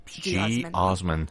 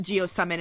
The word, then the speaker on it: geosmin: I want to say geosmin. – Geosmin. – Yes. Like, it's geosmin. – Sounds like a... – Or geosummon. Okay. Some people do pronounce it geosummon as well, or geosmin. And it's basically spelled G-E-O-S-M-I-N.